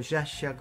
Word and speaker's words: Sciascia: Volvemos a hablar de Leonardo Sciascia, claro. O Sciascia, como lo pronuncian, sí, en Italia.